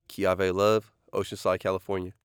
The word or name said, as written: Keavie Love